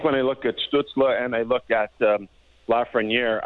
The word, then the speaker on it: Stützle: You know, obviously Lafreniere, Byfield, and is Stützle, give us the pronunciation. – Yeah, Tim Stützle.